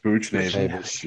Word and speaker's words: Buchnevich: Buchnevich.